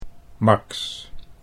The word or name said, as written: Max